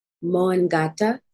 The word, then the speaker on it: Mångata: So I learned that it's, I hope I'm saying this right, Mångata. – Yes. Yeah. Kudos.